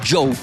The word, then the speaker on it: Djo: Esa canción se llama End of Beginning y el artista es Joe. Se escribe D-J-O, realmente es una forma complicada de escribir su nombre, que es Joe Carey, D-J-O, pero se pronuncia Joe.